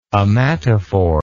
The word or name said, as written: ommatophore